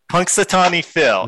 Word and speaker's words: Punxsutawney Phil: Punxsutawney Phil.